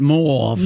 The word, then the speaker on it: mauve: And by the way, you do pronounce it mauve. – Mauve. Okay, good.